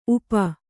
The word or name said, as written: ಊಪು